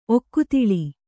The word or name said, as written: ಒಕ್ಕುತಿಳಿ